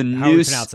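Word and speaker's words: Neuse: So it's the Noose News. Yeah, I know. Noose, it's N-E-U-S-E.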